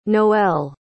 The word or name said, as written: noelle